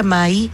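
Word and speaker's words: mãe: Entonces, Mai es mamá en portugués. Se pronuncia Mai y se escribe Mae.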